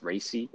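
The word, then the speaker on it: Raci: It's Racy. Is that how you say it? – Racy, bro. That is four fucking letters. Racy.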